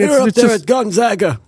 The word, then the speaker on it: Gonzaga: Especially since a lot of people mistakenly call it Gonzaga. – I called it Gonzaga until I heard your dad pronounce it in front of me once. And until then, I thought it was Gonzaga.